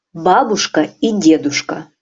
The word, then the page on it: babuska i deduska: Russian